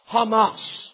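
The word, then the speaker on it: Hamas (חמס): Now, when you look at it in the Hebrew, it'll be spelled C-H-A-M-A-C in the English. But in Hebrew, C-H is always ha. C-H-A, ha, when it starts with it. For example, Hanukkah. You ever seen Hanukkah spelled with a C first? Why? Because it has the pronunciation as R-H. M-A-C is always pronounced mas, Hamas.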